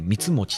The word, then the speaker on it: 光茂: 光茂と書いてあの光の茂と書いてね光持って読む